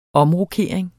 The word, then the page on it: omrokering: -ʁoˈkeˀɐ̯eŋ